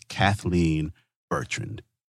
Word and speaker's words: Kathleen Bertrand: And with that, I'm going to shut up for a moment and introduce to Wild Black, Miss Kathleen Bertrand.